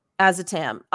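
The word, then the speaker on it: Azotam: Is it Azatam? – Yeah. – Azatam.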